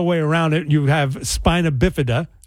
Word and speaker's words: spina bifida: I make sure, because I go, is it spinal or spina? – Spina.